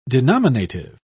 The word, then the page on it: denominative: British English